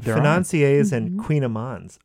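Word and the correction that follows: kouign-amann: are suddenly, or how do you say it? – Queen Amon, yeah.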